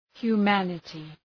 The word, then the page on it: humanity: hju:’mænətı